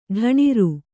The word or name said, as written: ಢಣಿರು